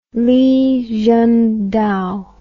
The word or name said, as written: Li Zhengdao